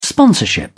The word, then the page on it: sponsorship: British English